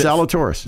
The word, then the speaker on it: Zalatoris: And then Zalatoris. I'm sure I butchered that. – No, it's Zalatoris.